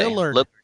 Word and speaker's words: Lillard: Lillard. – There we go. – So many drugs, Jonas. So many drugs. – Yeah, man. Lay off the weed. – Yeah, but I got Beal and Lillard for sure in there.